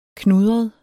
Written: knudret

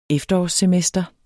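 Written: efterårssemester

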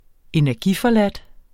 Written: energiforladt

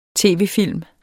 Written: tv-film

